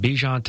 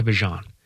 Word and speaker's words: Bijan: And we got that at the tail end of last season when Bijan Robinson went from Bijan to Bijan.